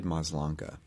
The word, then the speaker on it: Maslanka: By David Maslanka.